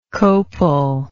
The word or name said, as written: copal